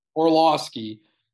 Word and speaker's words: Orlovsky: Orlovsky said that if they go back to the Super Bowl, it may be the greatest turnaround in North American sports history.